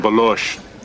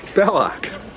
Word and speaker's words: Belloc: By coincidence, he is referring to Indy's rival, Belloc, but Salah mispronounces the name. – What's his name? – Well, we call him Balosh. – Belloc.